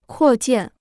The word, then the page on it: 扩建: kuò jiàn